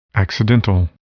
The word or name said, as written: accidental